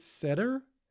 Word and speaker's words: Seder: Saturday Night Seder? S-E-D-E-R.